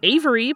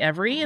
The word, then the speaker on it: Avery: You'll sometimes hear his name pronounced Avery, but it's generally Avery, and that's what we're going with.